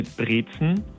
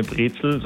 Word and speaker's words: Brezen: Dann eine Brezen, also ist keine Brezel, sondern eine Brezen.